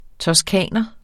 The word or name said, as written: toscaner